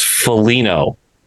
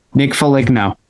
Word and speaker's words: Foligno: If you don't mind, it's Foligno. – Yeah. – So Nick Foligno, right? – He's earned the correct pronunciation of his name.